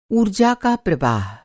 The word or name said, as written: प्रवाह